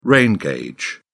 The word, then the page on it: rain gauge: British English